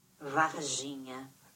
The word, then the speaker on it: Varginha: Varginha. – Varginha. Varginha? It's like, I need you to, it's like, I need you to not get it confused with Virginia whatsoever. It is pronounced the exact same way, though, apparently. No. Varginha.